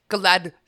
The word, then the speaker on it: Galadriel: It was Don't forget that it's pronounced Galadriel. – Galadriel.